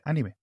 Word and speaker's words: anime: Sí, sí, sí, es que se pronuncia así. Se pronuncia así, macho. Se pronuncia anime.